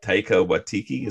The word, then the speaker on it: Taika Waititi: And I think that both like in Guardians of the Galaxy with James Gunn and with Thor Ragnarok with Taika Waititi, is that how you say his name? – Yeah.